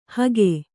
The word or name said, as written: ಹಿಕ್ಕೆ